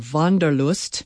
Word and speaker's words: Wanderlust: Borrowed directly from German, Wanderlust, or Wanderlust, as it's pronounced in the language, is a combination of wandern, to wander, and Lust, meaning pleasure, joy, or delight.